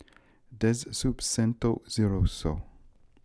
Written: Dezsoopsentoziroso

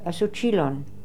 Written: Asoochilon

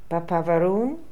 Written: Papaveroon

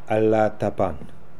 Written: Alla attapan

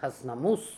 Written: Hasnamuss

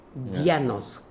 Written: Dionosk

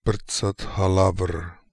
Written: Prtzathalavr

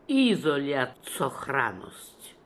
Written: Isoliazsokhlanness

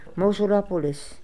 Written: Mosulopolis

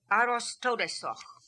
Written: Arostodesokh

